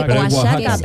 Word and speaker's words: Oaxaca: Sí, Oaxaca, pero se dice Oaxaca. – Eso. – Exactamente, con J, pero se escribe con X.